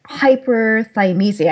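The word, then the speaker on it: hyperthymesia: Well, tell our audience, tell us a little bit more about hyperphimesia. Am I saying that right? – Yes, you're saying it right.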